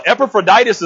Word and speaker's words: Epaphroditus: And I had to jot down, and I thought you guys would appreciate, even though my wife's not going to like it, Epaphroditus is not a disease. When I read it and read over it, and I actually pronounced the name right that time, so I was super proud of myself. That probably won't happen every time I say it. But, you know, it sounds like appendicitis, or it sounds like all these diseases that are out there.